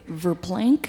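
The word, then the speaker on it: Verplanck: That's V-E-R-P-L-A-N-C-K. – Verplank? – Verplank, New York, 10596. Where's Verplank? – Verplank is on the Hudson River near Peekskill.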